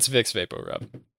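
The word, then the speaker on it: Vicks VapoRub: It's called Wix Weporub, I believe. Is that how you pronounce it, JM? Or is it Bix Baporub? – You've never had Vix before? – Vampirub. Vampirub is what it's called. It's Vix Vaporub.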